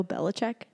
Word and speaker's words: Belichick: Bill Belichick? – Yeah.